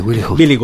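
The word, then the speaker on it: Wiligut: No sé cómo se dice, Billy Good. La W en alemán es B, no es como en inglés, ¿no? Billy Good.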